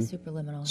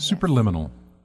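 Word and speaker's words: Superliminal: Super Liminal. – Super Liminal. It sounds like subliminal, but it's Super Liminal.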